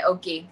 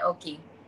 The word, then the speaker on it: Otsuki: My name is actually Natsune Oki. I go by Natsune Oki.